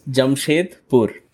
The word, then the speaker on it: Jamshedpur: So you were born in a small city in the northeast of India called, now I want to say this right, Jamshedpur. – Yeah, you yeah? – Almost got it. – Almost. – Yeah, so it's Jamshedpur.